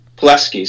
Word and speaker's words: Pulaski: This is in Kentucky, but it's actually in is it Pulaski County? – Pulaski is that how you say it? Mm-hmm. See, that's why you're here.